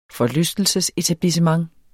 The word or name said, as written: forlystelsesetablissement